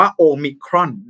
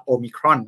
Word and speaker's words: โอมิครอน: ในภาษากรีกเนี่ยอ่านว่าโอมิครอนนะครับโอมิครอน